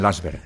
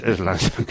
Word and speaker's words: Lansing: Sí, los que hablan mal dicen Lansberg. – Es Lansing. – Que no saben pronunciar bien.